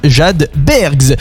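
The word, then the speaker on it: jade bergs: Ça s'écrit jadebergs, donc comme iceberg, J-A-D-E espace B-E-R-G-S, jadebergs.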